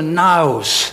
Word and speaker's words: naos: The Greek word is a naos. Phonetically, it'd be spelled naos, naos.